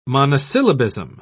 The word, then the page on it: monosyllabism: British English